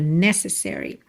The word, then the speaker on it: necessary: A palavra necessary tem a sílaba tônica na primeira sílaba, necessary. Só que como ela é uma palavra longa, a gente acaba tendo um outro apoio extra no se, Também, né? Necessary.